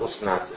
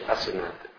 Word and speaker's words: Asenat: Giuseppe con chi si sposa la figlia di la figlia di Potifar, Asenat, mi viene adesso di quello Osnat, si dice Asenat, almeno secondo la pronuncia corretta.